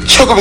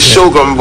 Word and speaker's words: CHOGM: I hear the British say Chogum is coming to Antigua, and we say Shogum, but they say it a bit differently than we.